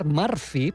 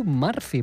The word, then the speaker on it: Murphy: Edward Murphy, perquè clar, això es diu Murphy, però nosaltres ho pronunciem Murphy, i ho continuarem pronunciant Murphy perquè és el nom... – A mi ara no em faràs canviar. – No, a mi tampoc, és el nom que ens ha quedat.